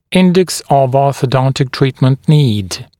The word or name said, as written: Index of Orthodontic Treatment Need (IOTN)